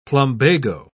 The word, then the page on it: plumbago: British English